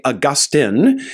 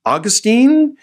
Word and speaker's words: Augustine: Both pronunciations are correct. The older pronunciation is Augustine, while Augustine is a more modern U.S. English pronunciation. We're going to stick with the older.